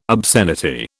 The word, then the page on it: obscenity: British English